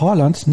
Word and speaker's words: Haaland: An der Stelle einfach ein Danke und Unterstützung an Sascha dafür, dass er Haaland nicht Erling Haaland ausspricht. Das A ist ein O wie in Dorf. Tatsächlich wird auch das D am Ende im Norwegischen nicht ausgesprochen, aber jetzt will ich nicht zu viel erwarten.